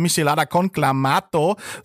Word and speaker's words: Michelada con Clamato: Die Rede ist von einem äh, klassischen Hangover-Cocktail aus Mexiko, Michelada Clamato oder Michelada con Clamato, so wird er Aha.